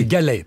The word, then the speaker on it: Gallet: Moi, mon contrat, je l'ai signé directement avec le président de Radio France, Mathieu Galette. – Non, d'abord, c'est Mathieu Gallet, pas Galette.